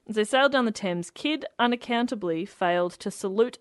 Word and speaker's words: Thames: It says, as the Adventure Galley sailed down the Thames. Right. – You said it right. – You nailed it.